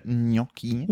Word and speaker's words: Gnocchi: Gnocchi heißt es eigentlich. Ja, aber ich habe immer. Ja, und ich habe aber, also. – Ja, aber ich finde Gnocchi.